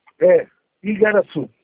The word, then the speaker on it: Igaraçu: Fala de novo para mim o nome da prefeitura. – Prefeitura Municipal de Igaraçu do Tietê. – Igaraçu? – É, Igaraçu. – Igaraçu, falei certo. Espera aí então.